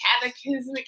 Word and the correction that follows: cataclysmic: Cataclysmic. – Cataclysmic.